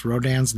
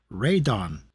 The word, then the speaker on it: Radon: Yeah, it says here on Wikipedia in past English releases, including dubbed films and video game titles, Rodan's name was always pronounced Rodan. In Japan, however, his name has always been pronounced and spelled in katakana as Radon.